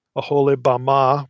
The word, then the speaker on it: Oholibamah: Again, maybe a little bit cryptically, but Esau's wife, her name was Oholibamah. Oholibamah would be a better pronunciation in Hebrew.